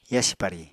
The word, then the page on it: yasi pari: apatani